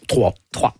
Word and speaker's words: Troyes: Vous commencez à jouer, bon, vous êtes né à Troyes, on dit Troyes en France, comment on prononce ? – Troyes. – Mais voilà, ça s'écrit YES en France.